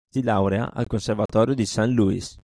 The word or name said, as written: laurea